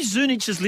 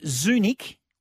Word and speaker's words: Zunic: 13 12 55, is it Zunich or Zunich? I think it's itch. – Yeah, I'm with you. – But it's spelt Z-U-N-I-C.